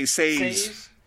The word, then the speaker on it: Saez: Your art is Jesus Saez. Is that how you pronounce that? – Yeah, Saez. I always say Saez.